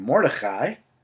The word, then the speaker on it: Mordechai: It's called Mordechai. I'm giving it the uh, Hebrew pronunciation because they spell it with a C and a H, which means it deserves the proper ch.